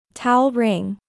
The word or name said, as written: Towel ring